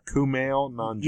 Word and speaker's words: Kumail Nanjiani: No, Camille Kamianjani from the Kumail Nanjiani. Yeah. – So I'm very sorry. I mispronounced it, but I knew I would.